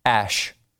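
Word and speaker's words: Æ: The other thing, he explained that the A-E, how would you pronounce that? Think in your head right now, how would I pronounce A-E? Yes, Ash.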